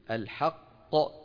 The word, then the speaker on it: الحق: والوزن يومئذ الحق، لما بنوقف عليها ننطق كم قاف؟ قافين. والوزن يومئذ الحق، الحق هذه القاف الأولى مدغمة، ط هذه القاف الثانية الموقوف عليها مقلقلة.